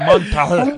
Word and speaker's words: Montreux: Montreux. – Montreux.